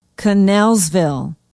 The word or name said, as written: knellsville